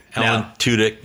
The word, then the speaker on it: Alan Tudyk: Alan Tudyk gets on the TV and you said it right.